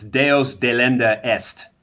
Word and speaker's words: Deus Delenda Est: And then I listened to, and I'm going to butcher this in my mush mouth, Deus De Lunde, whatever it is. – It's okay. It's Latin. It's Deus Delenda Est, which means God must be destroyed.